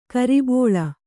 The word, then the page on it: ಕುರಿಬಳ್ಳಿ: Kannada